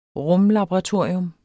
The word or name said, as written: rumlaboratorium